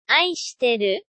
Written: itoshi teru

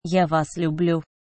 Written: ya vas lyublyu

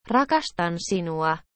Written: rakastan sinua

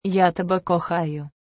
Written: ya tebe kokhayu

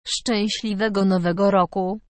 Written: szczesliwego nowego roku

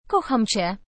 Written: kocham cie

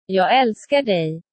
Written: jag aelskar dig

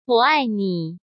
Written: wo ai ni

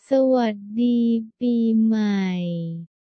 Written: sa was dee pee mai

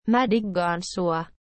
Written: mae diggaan sua

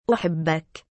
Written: uhibbak

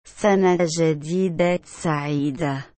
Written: sana jadida saida

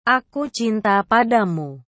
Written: aku cinta padamu

